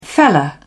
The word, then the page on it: fella: British English